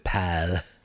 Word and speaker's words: Paul: And the serial killer's name is Paul. But they pronounce it pal.